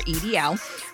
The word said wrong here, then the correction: EIDL: Wait, we pronounced it EIDL. My bad. I'm giving it totally new initials, Rebecca.